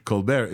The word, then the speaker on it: ColBERT: And the benefit here of ColBERT, finally I'm pronouncing this correctly, ColBERT is that instead of embedding one time, it embeds per token.